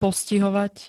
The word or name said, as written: postihovať